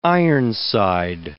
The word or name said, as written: ironside